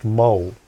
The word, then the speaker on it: mohel: That, by the way, that's pronounced mole.